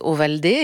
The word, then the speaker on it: Ovaldé: Ouais, mais parce qu'en fait, en français, si vous écrivez, ça s'écrit O-V-A-L-D-E, tout le monde prononce ça Ovalde. – Et c'est pas bien ? – Ça n'a pas tout à fait quand même la même musique. Et mon père m'a toujours dit qu'on s'appelait Ovaldé,